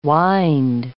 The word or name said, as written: wynd